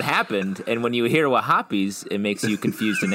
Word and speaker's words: Wahhabis: I think you said that wrong. It's Wahhabis.